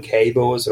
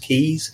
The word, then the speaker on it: Keys: Some people call them K boas. They're actually pronounced keys.